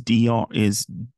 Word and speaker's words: Duair: And now the name of the place is Duair. I think that's how they say it. D-U-A-I-R.